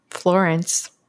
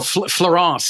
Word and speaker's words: Florance: And how about Florence? Is that how you say your name? – No, Florence. So Florence was you know, I thought it was important, a couple things.